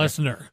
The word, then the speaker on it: Lesnar: Lesnar.